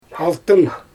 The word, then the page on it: altyn: Xyzyl